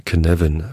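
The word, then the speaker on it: Cynefin: Und da gibt es ein schönes Modell, das heißt Kinevin Framework. Schreibt man C-Y-N-E-F-I-N. E ist ein walisisches Wort, habe ich auch schon mal genannt.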